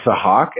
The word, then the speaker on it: Sahak: So my name's Sahak, S-A-H-A-K, okay. But a lot of people just call me Hawk, H-A-W-K.